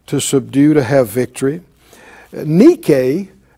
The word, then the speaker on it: nike: The word victory, the Greek word, is nike. And it's also the word that the popular athletic shoe company, Nike, Nike, well, that's how they're pronouncing it.